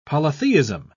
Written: polytheism

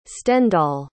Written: stendhal

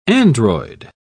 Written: android

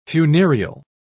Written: funereal

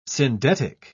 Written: syndetic

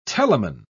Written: telamon

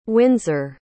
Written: winsor

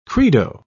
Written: credo

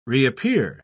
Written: reappear

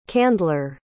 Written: candler

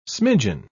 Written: smidgen

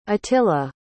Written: attila

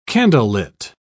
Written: candlelit

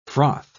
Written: froth

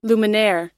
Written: luminaire

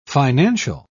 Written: financial

